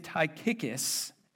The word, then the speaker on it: Tychicus: His name is Tychicus, and I promise you that is how you pronounce it, and if it's not, then you're wrong and I'm right. Na-na-na-na-boo-boo, all right? Tychicus will tell you, he's also dead.